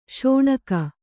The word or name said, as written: ಸೀನ್ಕೆ